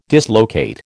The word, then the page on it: dislocate: American English